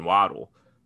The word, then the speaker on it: Waddle: I think they're going to go Jalen Waddle. Is that how I pronounce his last name? – Yep. I like Waddle.